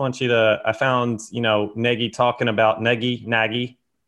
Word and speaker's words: Nagy: Please, everyone, especially sportscasters, stop calling the new coach Nagy. He told the Sun Times it's pronounced Nagy.